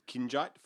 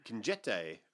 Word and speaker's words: Kinjite: Lee Thompson's Charles Bronson movie, which I've always said Kinjite, Forbidden Objects. – Yeah. – But then Quentin Tarantino's like, Kinjete, Forbidden Objects. And I'm like, have I been saying it wrong my entire life?